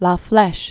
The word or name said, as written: La Flesche, Susette